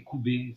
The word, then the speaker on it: Kubeh: Tiens, un restaurant euh, que j'aime beaucoup, c'est Koubé. Ça s'écrit K-U-B-E-H.